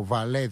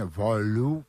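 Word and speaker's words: Wahlöö: Η Σχεβάλ Βαλέ, ευτυχώς δεν μας ακούει ο Γιαννήσης, ούτε ο Νίκος Γεωργιάδης φαντάζομαι, διότι η προφορά του ζεύγου Σχεβάλ Βαλέ είναι κάπως αλλιώς στα σουηδικά. Ο Βαλέ δηλαδή είναι Βαλού, κάπως έτσι μας τον είχε πει ο Βαγγέλης.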